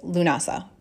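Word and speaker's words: Lughnasadh: And as I said, this is also called Lunasa, because in some traditions, this day honors the Celtic god Lu, L-U-G-H. And this is the celebration of the god referred to as Lunasa, which is spelled a few different ways, but generally L-U-G-H-N-A-S-A-D. Sometimes there's an H at the end.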